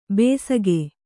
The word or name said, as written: ಬೆಸಗೆ